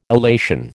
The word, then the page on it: illation: American English